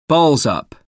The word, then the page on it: balls-up: American English